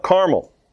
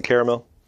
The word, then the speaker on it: Carmel: So Ahab sent to all the people of Israel and gathered the prophets together at Mount Carmel. Some people pronounce it Caramel.